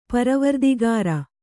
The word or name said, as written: ಪರವರ್ದಿಗಾರ